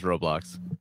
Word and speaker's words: Roblox: Okay, how do you say it? – Roblox. I don't know. Okay, Roblox. – All right. It's definitely just Roblox.